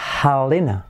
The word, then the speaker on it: Helena: Se dice Helena. Helena, ¿vale? Helena. Como parece gallina, ¿verdad?